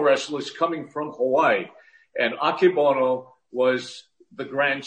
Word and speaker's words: Akebono: Aki Bono and Masu Naru. – Musashimaru. – yeah. That's great Japanese. Aki Bo- yeah, Akebono and Musashimaru.